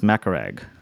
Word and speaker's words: Macaraeg: It's a Filipino, like there's a lot of Filipinos named Macaraeg. But here it's pronounced Macaraeg.